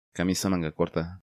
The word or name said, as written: Camisa manga corta